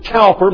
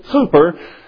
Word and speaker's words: Cowper: Many people would pronounce it Cowper, but the English pronounce it Cooper.